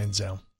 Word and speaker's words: Manziel: And celebrating on Sunday, Johnny Football Manziel is 28. Did I say that right? – Manziel.